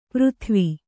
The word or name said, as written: ಪರಿದೇವ